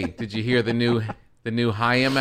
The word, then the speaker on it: Haim: She's like, it's Haim. Yeah, it's good though, right? Like, oh, I was so close. I knew the album, I knew the song, and I mispronounced the name by one syllable.